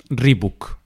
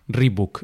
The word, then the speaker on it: Reebok: Reebok, Reebok. Ese book, Reebok, es más, más español, no es Reebok.